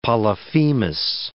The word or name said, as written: Polyphemus